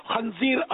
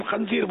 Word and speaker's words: خنزیر: وہ اصل لفظ خنزیر، تلفظ خنزیر عوامی طور پر عوام خنزیر بولتے ہیں،